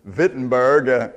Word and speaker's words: Wittenberg: or in the German, they, all the W's are pronounced. You know, we have Volkswagen, it's Volkswagen. So it's Wittenberg on October the 31st, 1517.